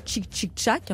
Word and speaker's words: Tic Tic Tac: Et il entend ce fameux titre, tic tic tac, prononcé donc tchic tchic tchac, hein, pour votre culture générale.